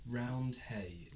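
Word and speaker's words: Roundhay: Round Hay Garden Scene. – Are you saying Round A? – Round Hay, like the place in Leeds, Round Hay Park.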